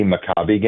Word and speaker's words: Maccabi: So what is the official pronunciation? – So, yeah, there's a variety of pronunciations that people will look at. But these specific games are the JCC Maccabi games.